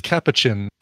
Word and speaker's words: capuchin: Like a little was it a Reese's monkey or a Sarge says capuchin. That's right, poochin. The emphasis is on the middle syllable.